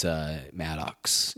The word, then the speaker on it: Maddux: and we saw Greg Maddux win his Maddux. You got to put the emphasis on the right? M-A-D-D-U-X.